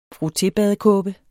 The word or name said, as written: frotté